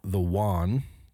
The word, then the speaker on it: yuan: dollar, to move into their own currency, or replace it with the yuan.